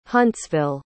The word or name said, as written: huntsville